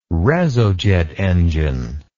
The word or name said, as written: reso jet engine